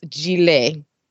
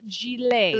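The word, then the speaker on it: gilet: The ET at the end is a long A, so it's Gillette. – Gillette.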